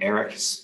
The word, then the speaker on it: Erich: So it's all with my full name, Gregory Erich Phillips, and Erich is spelled E-R-I-C-H.